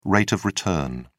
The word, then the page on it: rate of return: British English